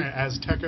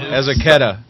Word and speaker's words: Azaceta: Azteca or something? – Azaceta. – Azaceta? – Yeah.